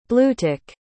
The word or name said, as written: bluetick